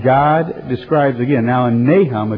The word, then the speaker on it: Nahum: You know, about 150 years after that experience, God has been talking about Nineveh again in a book in the scriptures called Nahum. Kind of rhymes with mayhem. But Nahum,